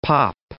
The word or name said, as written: pop